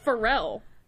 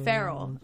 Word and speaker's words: Ferrell: I pronounce it Pharrell. – You're wrong. – Ferrell.